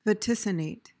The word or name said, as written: vaticinate